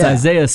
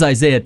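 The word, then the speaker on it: Isiah: It's Isaiah, spelled like Isaiah Thomas.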